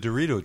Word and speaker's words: Dorito: And I also painted my I was just finishing up the Derridio dread. – Oh, the Dorito dread. – Yeah. – Sorry, you mispronounced it there.